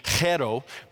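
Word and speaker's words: chero (χαίρω): That word in the Greek is kairo. In today's pronunciation, it would be chero, but it is written kairo in the Koine Greek in which Paul would have written it.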